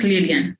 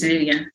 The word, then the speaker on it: Lilian: No, it's just Lilian. Swahili, okay. Swahili is Lilian, yeah.